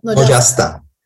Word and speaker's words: Nocastan: Özellikle Diran Çırakyan'ın hani Servilik adını taşıyan Ermenicesini hiçbir zaman söyleyemediğim için Nocastan mıydı? Nocastan diye mi okunuyor? – Nocastan.